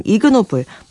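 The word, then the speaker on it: Ig Nobel: 이그노벨을 붙여서 발음하면 이그노블, 품이 없는 비열한이라는 뜻이 됩니다.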